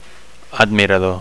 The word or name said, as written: admirador